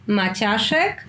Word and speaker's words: Maciaszek: Okay, so it's Olga Maciaszek Sharma. Usually the last part of my surname is the easiest to pronounce because Sharma, yeah, most of English speakers can pronounce Sharma, while Maciaszek is sort of difficult.